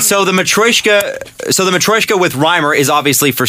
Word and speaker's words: Matryoshka: A Matryoshka.